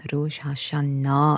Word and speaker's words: Rosh Hashanah: Daar is die Joodse nuwe jaar Rosh Hashanah, Rosh Hashanah uitgespreek met die klem op die laaste lettergreep Die strek gewoonlik oor 2 dae in September en dan word die skepping van die aarde gevier.